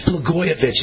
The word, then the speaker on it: Blagojevich: And Democrats and never Trumpers are shaking with rage as Trump commuted Rod Blagojevich, I'll call him Blago because I can't stand pronouncing his name. But it's Blagojevich is the way to pronounce it.